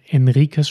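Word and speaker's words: Henriques: Ähm, Henriquez oder Enriquez spricht man wahrscheinlich, das H spricht man nicht.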